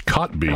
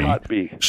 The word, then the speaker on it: Kotb: Name always threw me, but it's Cotby. – Cotby. – Spelled K-O-T-B. – Yeah, and you pronounce it C-O-T-B-E-E. – Cotby.